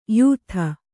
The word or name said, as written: ಯುತ